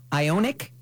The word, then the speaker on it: Ioniq: The Porsche Taycan Turbo and Hyundai Ioniq.